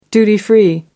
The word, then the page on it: duty free: American English